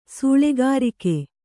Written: ಸಾಲಗಾರಿಕೆ

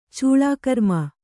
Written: ಚೌಲಕರ್ಮ

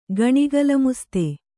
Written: ಗಣಿಗಲಮುಸ್ತೆ

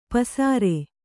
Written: ಪಸುರ್

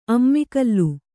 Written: ಅಮ್ಮಿಕಲ್ಲು

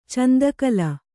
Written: ಚಂದಕಲ

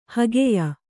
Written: ಹಗೆಯ

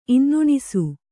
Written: ಇನ್ನುಣಿಸು

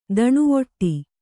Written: ದಣಿವಟ್ಟೆ

